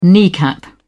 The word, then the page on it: kneecap: British English